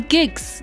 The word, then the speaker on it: Giks: I'm your girl Cynthia, but you know, like I always say, you can call me Gix. Yes, and Gix is spelled G-I-K-S.